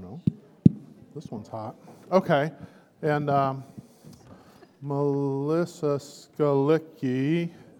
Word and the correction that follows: Skalicki: What, how do you say the name correctly? Skolitsky. Skolitsky. I, I missed that check. – well, okay. Skolitsky. That's right.